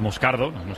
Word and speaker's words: Moscardo: Entonces, sí, como, como bien ha pronunciado Unai, es Moscardo, brasileño, sí.